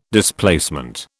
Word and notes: Displacement: British English

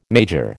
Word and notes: major: American English

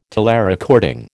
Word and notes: telerecording: American English

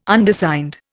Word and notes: Undersigned: Indian

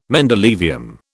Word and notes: mendelevium: British English

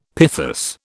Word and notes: pithos: British English

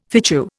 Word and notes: fitchew: Indian

